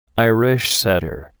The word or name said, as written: Irish setter